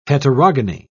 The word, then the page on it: heterogony: British English